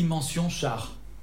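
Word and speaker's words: Mention-Schaar: De Marie Castille, mention char. Je prononce bien là C'est bien.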